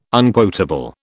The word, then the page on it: unquotable: British English